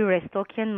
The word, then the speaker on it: Restokian: When you just write the word masks, M-A-S-K-S, Natalie Restokian, my name is spelled N-A-T-A-L-Y, Restokian, R-E-S-T-O-K-I-A-N.